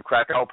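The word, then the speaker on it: Kraków: And it was titled Krakow. Krakow. Am I saying it right? Krakow. Krakow. – Krakow.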